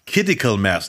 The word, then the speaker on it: Kidical Mass: Der hat noch Werbung gemacht für Kidical Mass. Nicht Critical, sondern Kidical Mass. Betonung auf Kid.